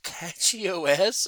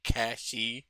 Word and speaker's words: Cachy: There's a new one called, I believe it's Catchy OS. Uh, it's spelled C A C H Y. so I'm, I'm sure it's like Cashy, but oh well.